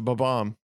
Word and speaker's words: Bob-omb: That's the way to pronounce it. Bob-omb.